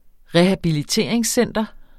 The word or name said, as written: rehabiliteringscenter